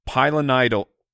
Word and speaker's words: pilonidal: In the offseason, he developed a, I'm going to try and say it right, pilonidal cyst.